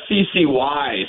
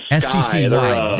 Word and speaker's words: SCCY: How do you pronounce that? – Oh, SCCY. – SCCY. There you go.